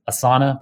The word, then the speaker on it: Asana: You got Twitter, Stitch Fix, Lyft, Pinterest, a company that everyone else pronounces Asana, but I say Asana, um, which I'm pretty sure is how you say that in Sanskrit.